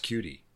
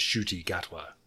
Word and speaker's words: Ncuti Gatwa: So I was hoping that name is pronounced cutie. But even Wikipedia gives you a good phonetic, and it is Ncuti Gatwa.